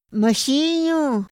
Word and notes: ma xinu: Otomí

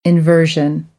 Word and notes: inversion: American English; ɝː

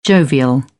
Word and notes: jovial: British English

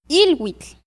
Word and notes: ilwitl: Náhuatl

